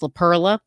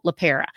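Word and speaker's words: LePera: By the way, in the last couple episodes, I definitely mispronounced her name and put an L in there and said it was LaPerla. That is not her name. It is Nicole LaPera.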